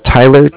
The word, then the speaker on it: Tyler: Okay, my name is Tyler, T-Y-L-E-R, and okay. – Calling with Cardiff. It's spelled C-A-R-D-I-F-F, as in Frank.